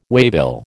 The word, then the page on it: waybill: American English